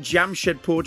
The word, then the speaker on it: Jamshedpur: Born in Jamshedpur, did I say that right, Atul? – Absolutely.